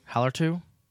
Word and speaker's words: Hallertau: Hellar. I can't pronounce that. H A L L. – Yeah, I think it's Hallertau. – Hallertau.